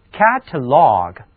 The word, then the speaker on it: catalog: catalog 它其中还有两个字母 A，第一个读作梅花音 A，第二个读作倒写的 A。